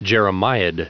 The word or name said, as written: jeremiad